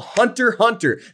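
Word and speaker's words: Hunter × Hunter: It's called Hunter Hunter. There's an X in the middle, but you don't pronounce the X unless you want everybody to know that you don't know what you're talking about.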